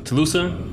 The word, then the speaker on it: Tulsa: What is Tuluska? Tulsa? – I was gonna say Tulsa.